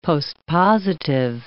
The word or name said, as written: postpositive